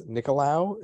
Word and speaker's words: Nicolau: Speaking of tough, flyweight is after that, and Matt Schnell is very tough with Matheus Nicolau. Is that how you say it? – Yes.